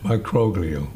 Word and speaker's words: microglial: Microglial. – Microglial. Very good on the activation. – It's a hard G.